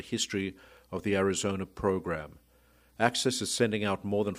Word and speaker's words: AHCCCS: or AHCCCS, pronounced ACCESS.